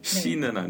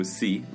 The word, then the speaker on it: see: 가끔 애들이 오히려 long time no see 보다 그리고 물론 발음은 she는 네. 아니고 see long time no see 근데 그것보다 이런 거 써요.